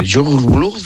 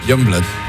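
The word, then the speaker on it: Yungblud: Es que era muy difícil de pronunciar, pero luego ¿cómo se pronuncia? – Youngblood.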